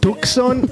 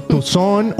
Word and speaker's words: Tucson: Eh, la pronunciación de acuerdo a nosotros los mexicanos que vivimos en el norte, le decimos Tucson, pero es, eh, los güeros acá le dicen Tucson.